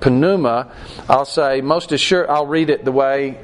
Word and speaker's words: pneuma: So now, again, we've missed something in English here, because this word Spirit is the Greek word pneuma. I'm pronouncing the P because that's what our guide in Greece told us we're supposed to do, although I think most Americans say not. We get our word pneumatic from this. It's spelled the same way, P-N-E-U, and that means air-actuated, pneumatic.